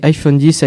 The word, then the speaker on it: iPhone X: L'iPhone X a prononcé iPhone 10 a été présenté lors du keynote Apple du 12 septembre 2017.